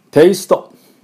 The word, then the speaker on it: taste: 次、今度はですね、テイスト、ね、日本語で味のことですね。テイスト、テイスト、これがまたちょっと違いますね、日本語の発音とね。実際はテイスト、テイストっていう音ですね。